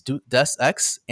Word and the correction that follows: Deus Ex: and Tomb Raider. Deus Ex. Thank you.